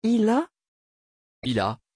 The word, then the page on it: hila: French